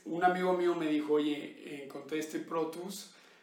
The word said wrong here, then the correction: Protos: Este, el, Protos, ¿no? – El, Protos, el Protos Gran Reserva.